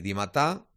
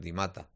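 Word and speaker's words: Dimata: Las 6 de la tarde, casi 20 minutos, hacemos una pausa, nos tomamos una estrella, escuchamos lo que ha dicho Rufete de Landry Di Matà, o Di Matà, como lo quieran pronunciar, hombre, es francófono, por lo tanto debe ser Di Matà, pero a uno le puede llamar Di Matà. O sea, como quieran ustedes.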